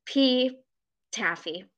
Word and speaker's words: pTaffy: And so the compromise was just I would add a silent P to my name. And I was like, it's like pterodactyl. It'll be great. And so it became the running joke that whole summer that everybody would be like, hey, pTaffy. And I would like pretend to be mad and like, it, the P is silent.